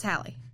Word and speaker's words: Halle: To reference a different couple, like, I don't know if you're familiar with DDG, and I always say her first name wrong, Halle Bailey? – It's Halle.